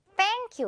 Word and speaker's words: thank: 우리 고맙다고 말할 때 정말 많이 하는 말 Thank you의 그 thank입니다. 이거 그냥 thank라고 하거나 너무 그냥 땡처럼 들리면 안 되고요. Thank 이렇게 혀가 반드시 밖으로 나와 있어야 돼요. 메롱을 꼭 해줘야 돼요, 여러분.